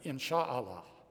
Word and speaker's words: inshallah: One of these words is inshallah. Let's say it together. Inshallah. If you really wanted to say it right, it'd be insha'Allah, but we're Americans.